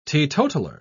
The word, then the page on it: teetotaler: British English